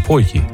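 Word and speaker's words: pôtje: Ja, want je mag er niet meer als een T uitspreken. Hè? Je moet echt een soort halve D zijn, een potje.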